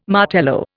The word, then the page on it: Martello: Indian